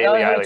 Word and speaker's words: Ehle: He said Ailey, he tweeted at us, E-H-L-E, Ailey.